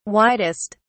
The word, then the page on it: widest: British English